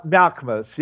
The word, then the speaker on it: Malkmus: One of the people dubbing Dylan is a guy by the name of Stephen Malkmus. I think that's how you pronounce his name. And he and a couple of Malkmus, yes.